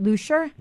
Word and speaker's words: Luscher: Which is Rachel, am I pronouncing it correctly, Lusher? – Rachel Lusher.